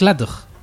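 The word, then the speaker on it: Ladj: De Lady, no es Lady, es Ladog.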